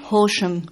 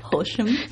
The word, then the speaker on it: Horsham: Horsham. – Horsham. – The English pronunciation, Horsham. That's right.